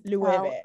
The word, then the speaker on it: Loewe: Of her saying Loewe in the mirror like over and over and over again because nobody know how to pronounce it because it's spelled Loewe, but it's Loewe. – Loewe.